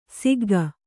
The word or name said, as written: ಸಿಗ್ಗು